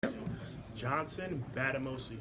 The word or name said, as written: Bademosi